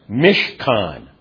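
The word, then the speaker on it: mishkan (משכן): And the dwelling is a participle that is pronounced mishkan.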